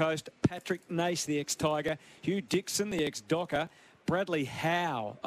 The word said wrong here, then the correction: Hoff: I believe it's pronounced. Hoff, I believe it's announced. I'll try that again. Brady Hoff will make his debut.